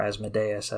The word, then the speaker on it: Asmodeus: they ended it by trying to assault Asmodeus, or Asmodeus as some pronounce it, and they failed, so his character had to have his soul plucked from hell to join the Dark Tower campaign.